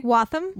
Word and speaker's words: Waltham: Waltham. – Waltham. And it's W-A-L-T-H-A-M. Mm. And I always want to overpronounce Waltham. – It. And be like, Waltham. Waltham.